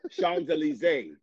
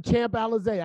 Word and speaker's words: Champs-Élysées: Okay, you was on Champs-Élysées. – Champs-Élysées. Say it right. No. – You was on Champs-Élysées.